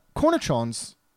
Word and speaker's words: cornichons: But here they put cornichons. That's how you pronounce them.